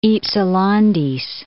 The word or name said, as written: Hypselantes